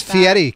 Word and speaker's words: Fieri: And it's Fieri, Kennedy.